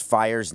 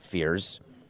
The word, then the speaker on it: Fiers: He said that Mike Fiers, I think it's E-I-R-S or I-E-R-S, and I think it's pronounced Fiers, not Fears.